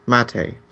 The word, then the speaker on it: MATE: I had Linux Mint 12 running on the laptop here with the Mat desktop environment, but it... it's Mate, sorry.